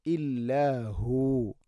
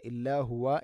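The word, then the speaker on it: إلا هو: ലാ ഇലാഹ ഇല്ലാഹു, അവിടെ വഖഫ് ചെയ്യുമ്പോൾ ഇല്ലാഹു എന്ന് പറഞ്ഞു, ഇല്ലാഹുവ എന്ന് വഖഫ് ചെയ്യേണ്ടതില്ല.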